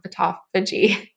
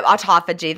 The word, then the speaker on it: autophagy: I definitely wasn't getting all the benefits that I'm now getting clean fasting with autophagy. – Yeah, autophagy. That's, you can hear other people say it differently. I think people in like Australia pronounce it differently, but yeah, I think autophagy is.